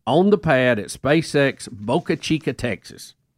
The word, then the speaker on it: Boca Chica: Boca Chica is right.